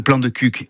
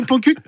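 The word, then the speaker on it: Plan-de-Cuques: Ainsi, prenez garde de bien prononcer « parle-moi de ton plan de cuc » et non pas « parle-moi de ton plan cuc ».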